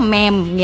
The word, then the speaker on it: meme: Soalnya spellingnya M-E-M-E jadi terusnya kayak gitu kan, M-E-M-E. Aku mikirnya tuh meme tuh bahasa Indonesia, sebelumnya. Makanya aku bacanya kayak meme. Ternyata yang benar gimana tuh? – The correct one is meme. – Oh, meme. – Just meme. – That's simple. – M-I-M.